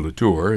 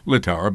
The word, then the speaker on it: Littauer: Although both men were Republicans, Jacob Snell and Gloversville Congressman and Fulton County glove industry mogul Lucius Littauer, or Latour, as we always said, but I believe the family likes it pronounced Littauer.